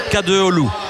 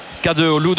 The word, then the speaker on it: Kadıoğlu: On va essayer Kadıoğlu, parce que c'est un I sans point et le G est muet. Kadıoğlu,